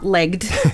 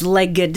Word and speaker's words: legged: So the pronunciation here is not legged. It's legged.